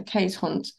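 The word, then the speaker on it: Keeshond: K's, sorry, is actually pronounced K's, and Hund is pronounced Hunt with a soft D, so K's Hunt.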